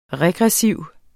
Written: regressiv

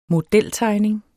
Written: modeltegning

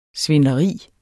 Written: svindleri

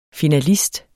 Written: finalist

